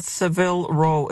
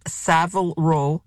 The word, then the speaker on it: Savile Row: It's not Seville Row. It's Savile Row. Seville is a city in Spain. The place where you can get really good, expensive suits, best suits in the world, Savile Row. Accent on the first syllable, and it's a letter A.